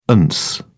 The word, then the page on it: ance: British English